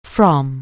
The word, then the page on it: frolic: anglais